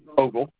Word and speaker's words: Ogle: Ogle. O-G-L-E.